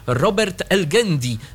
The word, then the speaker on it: Robert Elgendi: Robert Elgendi, tak to się wymawia, prowadzi serwisy i transmisje sportowe.